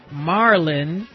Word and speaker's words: Mharlyn: And before that, we heard Look at What You've Done to Me from, I've been mispronouncing her name throughout this exchange, Marlyn, M-H-A-R-L-Y-N, Marlyn Merritt, Mike's sister, from their release Alone Together.